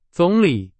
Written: 总理

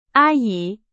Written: 阿姨

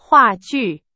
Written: 话剧

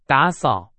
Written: 打扫

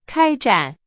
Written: 开展

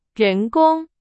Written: 人工

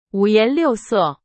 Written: 五颜六色

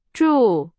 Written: 祝

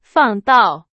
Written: 放到

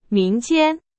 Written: 民间

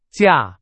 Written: 架

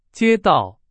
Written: 街道

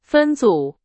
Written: 分组

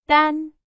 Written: 单

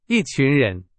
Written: 群

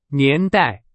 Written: 年代

